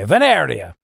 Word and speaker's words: Veneria: And that's how you pronounce it the Italian way. Veneria.